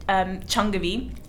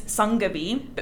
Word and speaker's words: Changavi: So my parents call me Changavi. – Changavi. – But the actual name is Sangavi. But because of numerology, it's spelled with a CH.